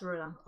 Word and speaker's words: Rodan: Rodan. – Yes, Rodan.